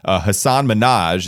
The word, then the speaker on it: Hasan Minhaj: Hasan Minhaj, an actor, comedian.